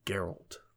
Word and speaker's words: Geralt: Oh, it's a hard G? – It's a hard G. – Wow, it's almost Gary? A soft G. Interesting. – Nah, it's Geralt. – Geralt. Sounds like a Geralt. – Like the sound you make for you throw up.